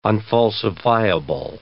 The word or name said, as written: unfalsifiable